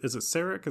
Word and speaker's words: Sarek: Is it Sarek? Is that how you say it? – Yeah, it's not garlic.